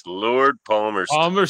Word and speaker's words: Palmerston: Oh, Palmerston? Is that how you pronounce it? – Yes, Lord Palmerston.